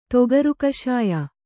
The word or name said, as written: ತೊಗರುಕಷಾಯ